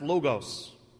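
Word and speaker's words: logos (λόγος): It's pronounced logos.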